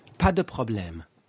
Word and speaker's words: Pas de problème: Pas de problème.